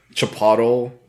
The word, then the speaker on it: chipotle: So you're sort of expecting like a chipotle experience.